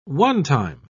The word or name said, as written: onetime